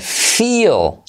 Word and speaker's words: feel: Entre las alternativas a I think, puedes decir I feel. Se escribe con E, pero se dice I, con letra clave L.